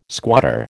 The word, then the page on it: squatter: American English